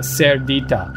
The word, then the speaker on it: Cerdita: Like P-I-G-G-Y. Although nice. In Spanish it's called, God, I'm going to pronounce this wrong because I can't roll my R's, but Cerdita, like C-E-R-D-I-T-A, which also means like little pig.